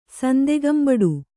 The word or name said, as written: ಸಂದೆಗಂಬಡು